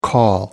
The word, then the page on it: call: American English